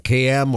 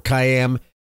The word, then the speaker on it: Kayyem: One of the key voices raising alarms on this issue was Juliet KM. Her last name is spelled K-A-Y-Y-E-M, KM or Kayem.